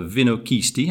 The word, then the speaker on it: Vinochisti: In the hot seat today is Tim Manning, founder owner of the Vinochisti. Have I said that right? – You have indeed.